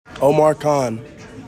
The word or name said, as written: OMAR KHAN